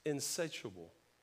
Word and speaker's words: insatiable: I'm saying it wrong, but my wife's already corrected me. I can't say, I say insatiable. It's insatiable, which means this an appetite or desire that is incapable of being satisfied.